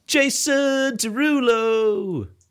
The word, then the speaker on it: Jason Derulo: Yeah, but I need to pronounce his name correctly, and that's Jason Derulo.